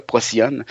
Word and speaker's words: Procyon: puis arrive sur Terre, puis est adopté par le professeur Prosikion. Je, me souviens plus de la prononciation, là. Excusez-moi si je la massacre, j'avais 4-5 ans. je, viens d'ouvrir Wikipédia, Procyon, c'est bien ça.